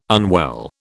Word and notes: unwell: British English

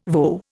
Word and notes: voe: Indian

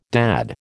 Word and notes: dad: American English

